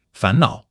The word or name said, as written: fan2 nao3